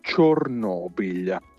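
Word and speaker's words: Chornobyl: in ucraino il suo nome è leggermente diverso, Chernobyl.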